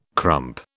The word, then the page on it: crump: British English